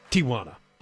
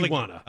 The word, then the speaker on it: Tijuana: I want to thank Alex Stone, ABC News for KFI, for correctly pronouncing Tijuana.